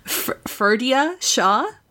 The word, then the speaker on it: Ferdia Shaw: He's going to be played by Irish newcomer, and I'm probably going to butcher this name because Irish names are never pronounced the way they look on paper. Ferdia Shaw? – Yeah. – What do you think? Hopefully. – I get that sounds right.